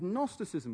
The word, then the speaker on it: Gnosticism: This is a key claim of a heresy which first became widespread in the 2nd and 3rd centuries, called Gnosticism, with a silent G.